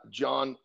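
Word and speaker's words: Jon: So if you want to connect with me, my Instagram, which is at the John Anderson, my name is spelled different.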